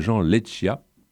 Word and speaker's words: Jean Leccia: une musique signée de Jean Leccia. Je pense qu'il vaut mieux le prononcer comme ça, cet homme est sans doute corse, donc susceptible.